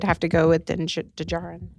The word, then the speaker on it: Djarin: Din Djarin. – Din Djarin. – Yeah, D is silent.